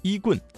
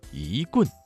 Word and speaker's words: 一棍: 一棍降妖的一字，一字在四声字前变成了二声，所以正确的读音不是一棍，而是一棍，一棍降妖。